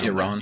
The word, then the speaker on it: Iran: Well, I think how do you pronounce Iran? – I think I say Iran. – It's usually a good rule to do the ah.